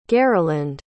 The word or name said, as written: gariland